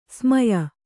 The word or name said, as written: ಸ್ಮಯ